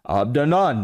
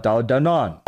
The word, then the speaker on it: Abdanan: Adnan, as Patrick Bedavid said, Abdanan, not Adnan.